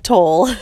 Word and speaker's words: Tolle: And yes, it is pronounced Tolle. I heard it from his own mouth.